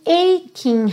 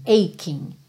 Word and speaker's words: aching: My aching heart, esse CHI vai ter um som correspondente ao QU pra gente aqui, ok? Aching, aching.